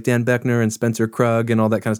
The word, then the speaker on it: Kroog: On his own label, pronounced Krug, spelled K R O O G.